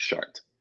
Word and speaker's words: Chartres: It's called Chartres. That's Chartres, pronounced Chartres.